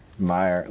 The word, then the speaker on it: Meier: Meyer. Look at that. He's got two E's. His name is spelled M-E-I-E-R, which is fucking bullshit.